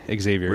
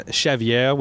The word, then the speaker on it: Xavier: But I went by Javier in Latin America because they cannot say Xavier. – Really? So. It's probably the same word. – Yeah. You could pronounce it Xavier, which I don't like.